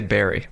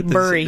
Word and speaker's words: bury: How do you say the word B-U-R-Y? – Well, I say Barry. – Do you? – Barry. – Yeah. – Because my husband, Andy, Burry. He buries things.